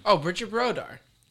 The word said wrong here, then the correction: Bardot: I don't even think I pronounce it right. – No, it's Bridget Bardot.